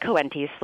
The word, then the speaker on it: Coenties: I pronounce it Coenties Slip, which is how...